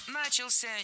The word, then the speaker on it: начался: 14.00 начался огромный парад вдоль набережный. – Начался неверно. Правильно, начался. Ударение в этом слове падает на последний слог, поближе к возвратной части «ся».